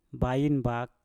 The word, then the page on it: bain bak: mpur